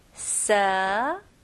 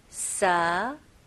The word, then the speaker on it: severe: 네, 여기서는 V 발음 해주셔야 돼요. 그래서 입술이 붙으면 안 되고 제 입 모양을 보세요. Severe, severe. 네, 여기서 스펠링은 S-E이지만 발음은 severe, severe. 그리고 R 발음까지 마무리 잘 해주셔야 됩니다.